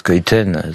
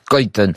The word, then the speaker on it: Schuiten: Et c'est là où ça a été un bonheur pour moi de rencontrer Françoise Cuiten, ce Belge dont le nom s'écrit Schuiten, mais il faut dire Cuiten,